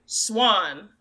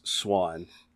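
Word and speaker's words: Siuan: At least that's the way it is in the official pronunciation guide. – Hey, we're going to go with that. You know, Swan. – I struggled with that one for years because it does not look like it should be pronounced that way. And in the audiobook, you actually get a few different pronunciations, but according to the official pronunciation guide, it is Swan. So, Just think of the bird. – Yeah, just like the bird.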